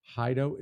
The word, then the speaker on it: HiDO: Hido, is that how you guys pronounce it? – Yes, sir.